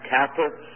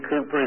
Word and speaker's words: Cowper: It's a hymn that was written by William Cooper, the name sometimes pronounced Cowper. William Cooper has written the hymn, "There is a Fountain Filled with Blood," which is one of my favorites.